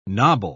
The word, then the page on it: knobble: British English